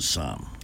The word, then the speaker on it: Somme: The Battle of the, is it the Somme? The Somme? S-O-M-M-E? – The Somme.